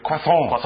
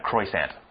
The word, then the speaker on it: croissant: The bakers created a special pastry, which would later be known as the croissant. Did I say that right? – Croissant. – Yes. – Don't say the T. – Croissant.